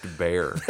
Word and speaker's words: Bexar: This is pronounced bear.